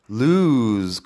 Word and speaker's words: lose: La diferencia, en cambio, es con la pronunciación de la S. Escúchalo bien. – Lose, de perder, loose, que es flojo o suelto.